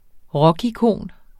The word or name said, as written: rockikon